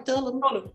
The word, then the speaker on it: Tulum: Tulum.